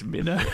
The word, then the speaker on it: Mino: How do you say it? – I think it's Minnow.